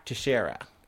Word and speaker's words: Teixeira: From ABC News, Air Force disciplines 15 airmen in investigation of accused leaker Jack Teixeira.